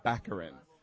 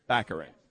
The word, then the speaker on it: Baccarin: So I'm here at the Super Mega Show with Morena Baccarin, and I'm pronouncing it correctly because before this interview, I asked her if I was pronouncing it correctly because I want to be the person that makes sure that everyone knows that it's Baccarin. Baccarin. – Yes, that's right. You got it.